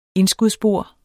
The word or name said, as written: indskudsbord